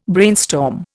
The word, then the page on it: brainstorm: Indian